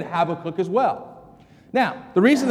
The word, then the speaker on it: Habakkuk: He informed the congregation that the correct pronunciation of the book that we're studying today is Habakkuk.